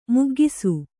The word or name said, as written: ಮಗ್ಗಿಸು